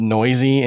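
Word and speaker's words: Neuse: By the way, if you're trying to figure out how to spell that, that's N-E-U-S-E, and it's pronounced noisy.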